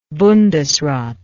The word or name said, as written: bundesrat